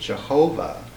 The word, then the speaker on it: Jehovah: And of course, in German, our Y sound is a J, as in Yashir Yibetcha. And in German, W's are pronounced as V's. And that's how we come up with Jehovah, was Luther's very arbitrary making this a pronounceable sound.